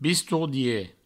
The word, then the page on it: bistordier: Provençal; bistourdie